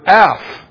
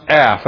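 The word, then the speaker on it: af (אף): And this day of wrath here is the Hebrew word af, which, strange, A-P-H, pronounced af, that's an easy one, A-F, which is a noun masculine, and is in reference to God's face, nostrils, or anger.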